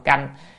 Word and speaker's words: canh: À, đọc cánh là nó dư, tại vì chữ phục là lại rồi, cánh nó lại nữa, lại lại thì không phải. Cho nên nên đọc là âm canh, và thường hình như cũng chú giải là âm canh.